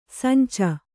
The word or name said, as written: ಸಂಚು